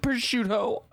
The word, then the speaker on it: prosciutto: It's pronounced prosciutto.